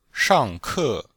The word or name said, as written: shang4ke4